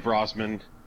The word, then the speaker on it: Brosman: Um, I, I always like to say that there's about 12 ways to pronounce my name. Uh-huh. And I, I can't prove that any one of them is incorrect. um, we've always said Brosman.